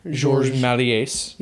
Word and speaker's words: Méliès: George Méliès. – And they seem to pronounce the S. – Because I think I thought about that because I would say Méliès for a long time, but it's not, there's no T at the end, which is the traditional French for, you know, it's the silent T for like ballet, buffet, you know.